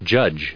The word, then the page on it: judge: American English